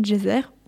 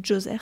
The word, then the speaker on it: Djoser: La plus ancienne pyramide en pierre a été construite à Saqqara vers 2800 avant Jésus-Christ pour le pharaon Djoser, ou Djoser selon les prononciations, conçue par l'architecte Imhotep, dont on a déjà parlé.